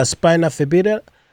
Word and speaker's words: spina bifida: But just to get into you, to understand you, going through spina bifida, And how do you say it correctly? Is it spinal? – It's spina. S-P-I-N-A and then bifida. B-E-F-I-D-A. Yes, thank you.